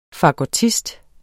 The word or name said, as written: fagottist